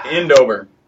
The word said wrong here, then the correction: Eindhoven: Endover, really? – Yeah. Or Eindover. – Eindhoven. Because I was going to be like Eindhoven. I don't pronounce European cities very often. – No, so. – That's not. You're right. No, you're right. Eindhoven.